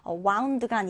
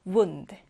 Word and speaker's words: wound: wound가 아니라 wound, wound입니다.